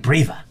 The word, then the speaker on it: Breeva: I'm Breva. – Breva. – Yes. – Like the cold medicine, cold sore medicine. – I suppose. – How do you spell that? – B-R-E-E-V-A.